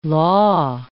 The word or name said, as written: la